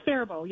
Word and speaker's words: Faribault: It's Faribault. Yep, it's French.